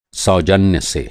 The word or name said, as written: फ़ासला